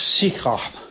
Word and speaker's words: Shechem: Now, what does, in Hebrew, it's really spelled a little bit different in this particular case. We would pronounce it as Shechem.